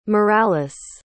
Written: Morales

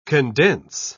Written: condense

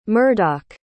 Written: murdoc

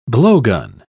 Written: blowgun

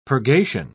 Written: purgation

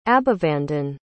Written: abhivandan

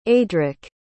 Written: adrik

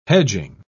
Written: hedging

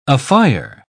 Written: afire

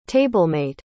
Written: tablemate

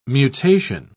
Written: mutation

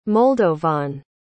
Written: moldovan

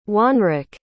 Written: wanrrick

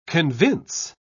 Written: convince

